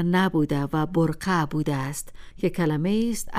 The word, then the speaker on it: برقه: پس به خاطر داشته باشیم که بقره یک تلفظ عامیانه از کلمه کهن و عربی برقه است و فرهیختگان جامعه بهتر است بکوشند آن را به صورت درستش به کار برند.